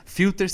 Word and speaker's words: Filtr: Filter se escreve sem o E, tá? É F-I-L-T-R. Então falta o E de filter, é filter.